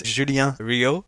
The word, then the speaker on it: Julien Rio: As a quick word of intro, so as you said, beautifully, because you managed to pronounce my name in French, as it should be. My name is Julien Rio, and I am Senior Director of Marketing for RingCentral.